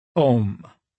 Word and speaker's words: Om: Since in Sanskrit, A and U coalesce in O, the sacred syllable is pronounced and often written Om, spelled O-M. The syllable itself is God as creator, preserver, destroyer.